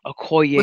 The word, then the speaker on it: Okoye: And did you see what's it, Okio? Oki? Okio? How you pronounce that? Okoye.